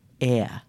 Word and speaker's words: air: And uh, Jessica Mutch McKay very kindly pointed out that um, I was pronouncing ear the same way I pronounce ear as in a taringa that you listen with. So I've learnt how to say air.